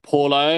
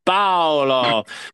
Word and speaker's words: Paolo: We live in the UK, and it's so funny how the white British people just pronounce it Paulo. And I'm just like thinking, just because your white asses can get used to the name Paul doesn't exactly mean by adding the O it's pronounced like that. You know, this is why I'm the spot. because I'm just like spicy. It's Paolo. Put the emphasis in it, honey.